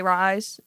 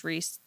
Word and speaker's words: Rhys: It's R-H-Y-S. – R-H-Y-S? Okay, yeah. – A lot of people say Rise, but it's Reese.